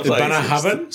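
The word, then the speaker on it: Bunnahabhain: Bunna Habhain? – Stop saying words. Yes. – Bunna Habhain. Is this Welsh spelling? – No. Because Gaelic. – Gaelic. – Gaelic spelling? – That Bunna Habhain.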